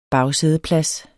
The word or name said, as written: bagsædeplads